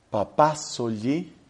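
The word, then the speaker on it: Papàsogli: A mí me impactó y me parece de gran utilidad la que escribió un italiano, Giorgio, es el nombre de él, Giorgio, se pronuncia Giorgio, Giorgio Papazzoli, es la pronunciación del apellido y la escritura es Papazzoli.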